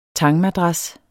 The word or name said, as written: tangmadras